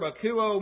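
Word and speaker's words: akouo (ἀκούω): It's from two words in the Greek, from the word hupo, meaning under, and the verb akouo, meaning to hear.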